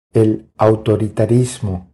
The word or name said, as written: El autoritarismo